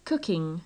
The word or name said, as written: cooking